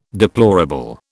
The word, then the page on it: deplorable: British English